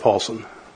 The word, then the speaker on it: Paulson: I'm sorry, it's Robert Paulson. – And how you spell that? – Robert, the common spelling, and Paulson, P-A-U-L-S-O-N.